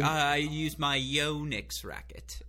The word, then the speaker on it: Yonex: And so, yeah, it is, like you said, named after him, and it is Yonex. So I was corrected this morning, and I didn't know that. So now I feel like I'm a much more educated, sophisticated tennis fan now that I can pronounce it correctly.